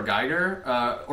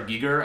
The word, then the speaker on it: Giger: But he's very influenced by H.R. Giger, or H.R. Giger, I think is technically how it's pronounced.